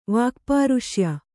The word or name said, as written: ವಾಕ್ಪಾರುಷ್ಯ